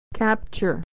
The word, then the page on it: capture: American English